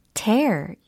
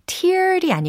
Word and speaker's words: tear: tear, 예, t-e-a-r, tear. 이걸 한번 활용을 해보는 거예요. tear이 아니고 tear이라고 발음을 하셔야 동사적인 의미를 전달하실 수가 있습니다.